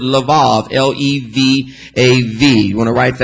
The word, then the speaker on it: Levav: L-E-V-A-V. V as in Victor. L-E-V as in Victor. A Apple V Victor, Levav. It's pronounced Levav.